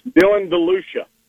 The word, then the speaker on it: DeLucia: Is it DeLucia? – It's Dylan DeLucia. – DeLucia. – DeLucia.